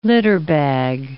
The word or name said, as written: litterbag